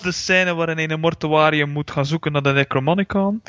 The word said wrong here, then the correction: Necronomicon: En dat kan je dus ook al niet uitspreken. Jij en jouw belachelijke... – Necronomicon. – Dat toch nog, ja.